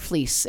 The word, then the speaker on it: Flies: Heather Fleece, and fleece is spelled like flies.